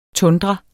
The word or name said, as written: tundra